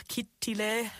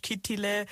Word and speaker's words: Kittilä: Also wir sind zum Beispiel nach Kittilä, so spricht man das auf Finnisch, also auf Deutsch Kittilä, aus, sind wir hingeflogen von Düsseldorf aus, weil es ab Köln keine Direktflüge gab.